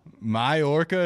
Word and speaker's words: Mallorca: Against Mallorca? – Mallorca is how you say that? – Yeah, I'm out.